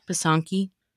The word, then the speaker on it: pysanky: Pysanky.